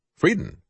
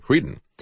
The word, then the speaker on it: Frieden: Frieden, I guess is the way he pronounced his name, Frieden.